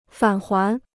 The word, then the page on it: 返还: fǎn huán